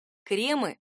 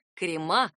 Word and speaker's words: кремы: Кремы действительно помогут избежать проблемы. Проблема неправильного ударения. Литературным вариантом считается именно кремы с ударением на «е». Большой орфоэпический словарь русского языка 2012 года в скобках отмечает «крема». Это неправильно.